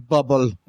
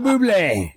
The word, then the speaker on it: Bublé: It's pronounced Michael Bubble. – You know what I mean. – Michael Bublé.